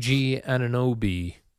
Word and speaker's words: Anunoby: So Knicks tonight, you mentioned, Jerry, OG Anunoby.